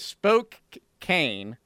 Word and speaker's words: Spokane: It is Spokane with a can at the end.